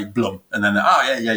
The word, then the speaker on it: Blum: Really, the official pronunciation is Bloom. Um, many many people out there know us as Blum,